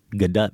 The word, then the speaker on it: Gadot: We are going with Gadot because we heard her say that on Jimmy Kimmel. – Confirmed. – We can show you the link. That's how she pronounces her name.